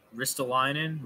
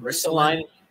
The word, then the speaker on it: Ristolainen: Um, but again, as far as far as uh, as far as uh, Ristol, as far as how do you pronounce his name again? Ristolainen. – Ristolainen.